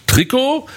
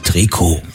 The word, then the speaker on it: Trikot: Jahrhundert setzte sich der Begriff für eng anliegende Sportkleidung durch, weil diese eben aus dem dehnbaren Strickstoff hergestellt wurde und weil im Französischen in der Regel die letzte Silbe betont wird, heißt es richtig Trikot.